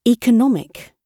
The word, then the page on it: economic: British English